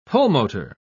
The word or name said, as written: pulmotor